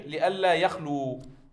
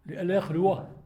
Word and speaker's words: لئلا يخلو: إذن على هذه الصورة يكون النطق الصحيح لئلا يخلو لئلا يخلو. – نعم يخلو. – نعم لئلا يخلو هذا.